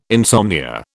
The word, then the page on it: insomnia: British English